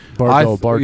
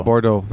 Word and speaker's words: Bordeaux: Bordeaux. I can't pronounce her last name. Thank you. – Bordeaux. – Yeah, Bordeaux. Thank you.